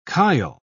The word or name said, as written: kyle